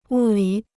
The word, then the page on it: 物理: wù lǐ